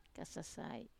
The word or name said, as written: kasasai